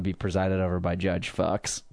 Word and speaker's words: Fuchs: F-U-C-H-S.